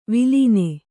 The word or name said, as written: ವಳನ